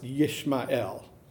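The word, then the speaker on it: Yishmael (ישמעאל): Now, first of all, in Hebrew, his name would be pronounced Yishmael, okay?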